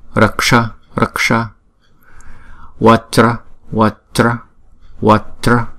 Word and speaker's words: vajra: Chữ J này chúng ta đọc là J, J. Ráp lại là hai âm là Vajra, Vajra. Chứ chúng ta không đọc là Vajra. Nếu mà chúng ta đọc Vajra thành ba âm rồi, đúng không? Chữ này là hai âm Vajra, Vajra.